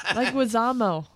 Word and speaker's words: Leguizamo: Leguizamo. – I said it right? – No. Leguizamo.